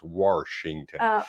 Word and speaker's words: Washington: It's actually, we don't merge syllables together. We put an R in, so it's Washington.